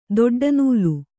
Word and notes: ದೊಡ್ಡನೂಲು: doḍḍa nūlu

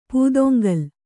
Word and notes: ಪೂದೊಂಗಲು: pūdoŋgalu

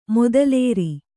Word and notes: ಮೊದಲೇರಿ: modalēri

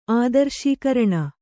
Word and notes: ಆದರ್ಶೀಕರಣ: kannada

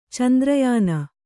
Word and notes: ಚಾಂದ್ರಾಯಣ: cāndrāyaṇa

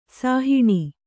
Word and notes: ಸಾಹಣ: Kannada

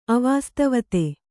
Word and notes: ಅವಾಸ್ತವತೆ: avāsatavate

